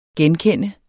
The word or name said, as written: genkende